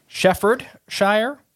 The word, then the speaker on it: Staffordshire: Staffordshire. I think that was probably the most correct one because the D I wasn't pronouncing the first two times.